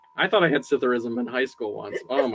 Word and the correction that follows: psithurism: It's Psithurism. – What is Citharism? – There's a silent P.